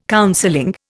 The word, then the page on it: counseling: Indian